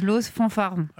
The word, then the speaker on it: Los Fanfarons: Mais il faut le prononcer à la française. Los Fanfarons.